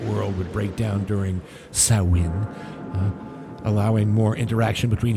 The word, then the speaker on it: Samhain: Halloween is actually based on an ancient Celtic holiday called Samhain, although it's spelled S A M H A I N. Leave it to the Celtic to make Samhain pronounced Samhain.